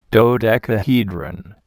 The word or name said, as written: dodecahedron